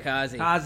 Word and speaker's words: Communicozy: Communicozy. It's like a blend of the word communication and kamikaze.